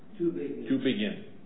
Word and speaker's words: Tübingen: Tübingen.